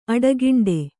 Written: ಅಡಿಗಂಟು

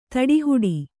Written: ತಡೆಹಿಡಿ

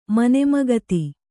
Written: ಮನೆಮಗತ್ತಿ

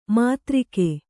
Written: ಮಧುರಿಕೆ

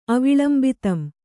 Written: ಅವಿಳಂಬಿತಂ

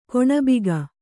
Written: ಕೊಣಬಿಗೆ

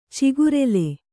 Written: ಚಕರಾಲಿ

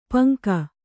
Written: ಪಂಕ

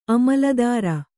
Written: ಅಮಿಲ್ದಾರ

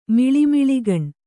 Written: ಮಿಳಿಮಿಳಿಗಣ್

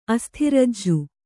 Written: ಅಸ್ಥಿರಜ್ಜು

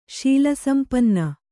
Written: ಶೀಲಸಂಪನ್ನೆ

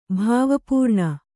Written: ಭಾವಪೂರ್ಣ

